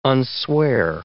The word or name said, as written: unswear